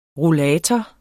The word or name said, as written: rollator